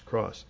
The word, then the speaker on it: cross: One syllable. Rhymes with loss, cross.